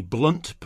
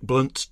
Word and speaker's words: Blount: And Maria's True North Music is promoting a tour by Jake Blount, but you pronounce it apparently Blunt, spelt B-L-O-U-N-T. That includes the Marsden and Americana Festival near Huddersfield, 22nd of June. Didn't It Rain from Jake Blunt, 2022, The New Faith.